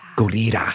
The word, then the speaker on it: Gurira: Danai Gurira. Gurira. – Mm-hmm. – She taught me how to say it. She was like, double roll the R.